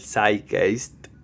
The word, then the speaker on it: Zeitgeist: Y ustedes se preguntarán qué carajo es el Zeitgeist. Ahí se lo dije bien, es una palabra que proviene del alemán y significa el espíritu del tiempo.